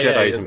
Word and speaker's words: Jediism: Am I saying that right? Because I've never seen the word Jediism before. Yeah, okay. – Yeah, no, that's right, yeah.